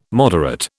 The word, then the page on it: moderate: British English